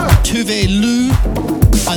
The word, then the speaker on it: Tove Lo: And then after that, Tove Lo, or Tove Lo as it's apparently pronounced, with a track called Disco Tits, which has been in my head in a big way the last few weeks.